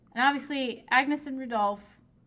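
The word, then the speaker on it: Agnès: Whose name in French is pronounced Agnes, but because I'm lazy, we're calling her Agnes.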